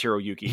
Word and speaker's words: Hiroyuki: Hiroko Sanda, I don't know if that's exactly how you say it. – I believe it's Hiroyuki. – Hiroyuki, okay. Who both have a profound history with John Wick.